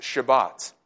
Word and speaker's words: Shabbat: In Hebrew, the word is actually pronounced Shabbat.